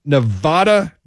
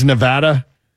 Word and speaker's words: Nevada: Villanova at number 10, Michigan State number 9, North Carolina number 8, Virginia number 7, Nevada. Did I say that right? – No. – It's Nevada.